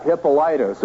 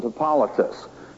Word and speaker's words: Hippolytus: And then you've got Hippolytus, or some pronounce it Hippolytus, who writes just a little later than Justin Martyr.